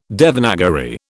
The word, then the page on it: Devanagari: British English